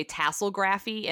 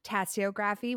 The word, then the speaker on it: tasseography: I'm going to pronounce this wrong because every single time I want to say tasselgraphy and it's not tassels. Okay. Tasography. – You can pronounce it three ways. – Okay. – So I say tasseography, which bothers a lot of people, but tough. That's okay. How I say it.